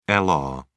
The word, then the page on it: lr: British English